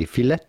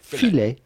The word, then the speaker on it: filet: The T at the end of filet is definitely silent, so do not say filet, but rather filet.